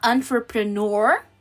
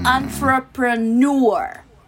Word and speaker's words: entrepreneur: A gente pode falar entrepreneur ou hum. entrepreneur. – Com U mais pronunciado ou sem esse U mais pronunciado.